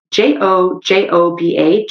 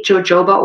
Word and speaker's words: jojoba: You buy jojoba oil, which is spelled J O J O B A, jojoba oil or jojoba oil.